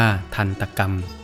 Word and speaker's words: ทันตกรรม: ทันตกรรมทันตกรรมแปลว่าการลงอาชญาการทำโทษการลงโทษด้วยไม้การบวยตีทันตกรรม